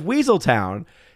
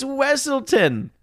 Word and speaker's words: Wesselton: And so the whole concept of the film in Frozen and his character is that they're constantly mispronouncing his name. And they keep saying, oh yeah, it's the Duke of Weaseltown. And he's like, it's Wesselton.